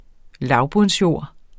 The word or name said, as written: lavbundsjord